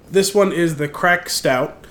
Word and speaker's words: craic: It's spelled C-R-A-I-C. – Crack. Yeah. I was never really sure. Gaelic is weird.